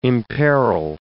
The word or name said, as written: imperil